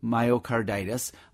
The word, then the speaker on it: myocarditis: All right, another texter writes about a giant cell, and I'm not going to say it right, myocarditis? – Myocarditis, yeah, that's not common.